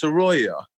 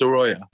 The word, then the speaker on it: Saroea: It's actually pronounced Saroea. – Saroea. – Which is kind of weird because it's not spelled Saroea. but it is actually pronounced Saroea.